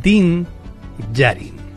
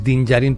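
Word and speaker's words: Djarin: Din Yarin. El apellido empieza con DJ, pero al igual que ocurre con Django, pues la D es muda, con lo cual es Din Yarin, pero se escribe, digamos que las siglas son DD, como Devil.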